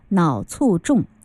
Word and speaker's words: 脑卒中: 不过在这里呢，有一个修正哈，这个病呢叫脑卒中。其实三个字当中有两个是多音字哈，很多人都会有误读啊。那这个我想很多人就是任何事情哈出现呢都会有一些征兆的哈，就像脑卒中也是，它一定也是有前兆，只不过是我们有没有注意到而已哈。